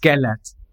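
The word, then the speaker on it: Gellért: Is it Gellert or Gellert? – It's Gellert.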